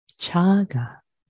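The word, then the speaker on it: cāga: One should cultivate relinquishment / generosity, chaga, chaga, in Pali, C-A-G-A. C is pronounced as cha, chaga, chaga, the long A is the first A. And the fourth aditana, fourth resolve, one should train for peace, upasama.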